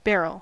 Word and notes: barrel: American English

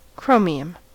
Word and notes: chromium: American English

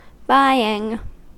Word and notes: buying: American English

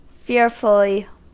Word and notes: fearfully: American English

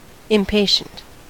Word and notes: impatient: American English

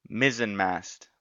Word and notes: mizzenmast: American English

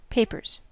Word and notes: papers: American English

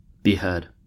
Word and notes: behead: American English

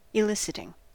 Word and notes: eliciting: American English

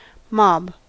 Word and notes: mob: American English

